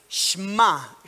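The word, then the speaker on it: Shma (שמע): Hay una palabra eh, eh, en hebreo, estuve tratando de escucharla para decirles cómo se pronuncia, es Shma, Shma, es rara para, hasta para escribirla, S-H-M-A, Shma, que es oír, pero oír y responder apropiadamente.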